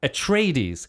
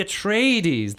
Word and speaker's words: Atreides: Again, I'm like, no, it's the Atreides, the Harkonnens. Yeah. Because, of course, we're like, I speak English and we put the stress on like that third syllable when in the longer ones.